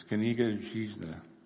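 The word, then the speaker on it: Kniga Zhizna (Книга жизни): It's Kniga Zhizna, is how it's pronounced in Russian.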